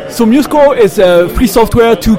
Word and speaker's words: MuseScore: Hi, I'm at the Muse Score booth and I'm talking to...